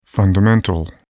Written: fundamental

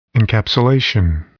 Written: encapsulation

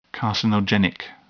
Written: carcinogenic

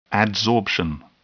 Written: adsorption

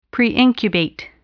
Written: preincubate

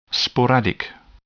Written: sporadic

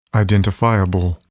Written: identifiable

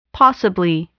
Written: possibly

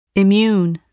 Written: immune

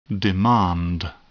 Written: demand

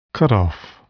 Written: cutoff

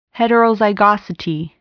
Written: heterozygosity

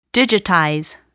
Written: digitize

